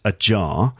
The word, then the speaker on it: ajar: When it's a jar. But also we have the expression a jar, which is one word, same letters, but it's all one word, and it's pronounced almost exactly the same, and that means slightly open.